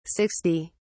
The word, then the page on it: 60: British English